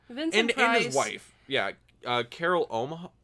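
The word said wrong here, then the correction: Ohmart: Omart. – Oh, that's how you pronounce it.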